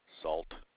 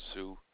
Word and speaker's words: Sault: It's spelt Sault. Salt. It's pronounced Sioux, but Sioux.